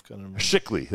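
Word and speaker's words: Chikli: Shickley. They call him Shickley. Like it's S-H, but it's spelled with a C-H in English.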